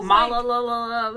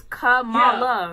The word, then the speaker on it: Kamala: I was Malala. That's like, girl, it's Kamala. Yeah, like, Kamala. Kamala Harris.